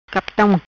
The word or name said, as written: Captan